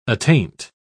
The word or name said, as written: attaint